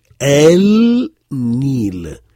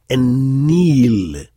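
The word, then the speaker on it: En-Nil (النيل): Mais en réalité, il faut prononcer En-Nil, Et-Tin. C'est un phénomène phonétique qui fait que le lam de l'article cède la place à la première consonne du nom, et donc on prononce deux fois le nun pour ennil et deux fois le ta pour ettin.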